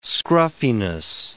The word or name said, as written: scruffiness